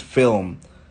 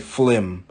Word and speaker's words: film: Like, for instance, film. We never used to say film. We used to say flim. Why do we call it a flim? It's not F-L-I-M. It's F-I-L-M. Film.